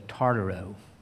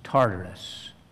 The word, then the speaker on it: Tartarus: The Greek word is Tartarus, or actually the Greek word is ends with two O's instead of a U-S when it's transliterated, so it's Tartaro. But in English pronunciation, it is Tartarus.